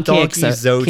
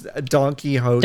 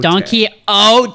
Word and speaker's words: Don Quixote: Donkey I don't know how that's pronounced. It's called Donkey Zote. – Quixote. – That's Don Quixote. – Don Quixote.